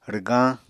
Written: rwgañ

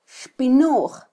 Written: xpinuj

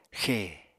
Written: xe

